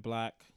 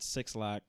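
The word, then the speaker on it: 6LACK: This one is featuring Black. It's pronounced Black, not Six Lack or some weird ass way to pronounce it. It's Black. It just spells with a six for some fucking reason.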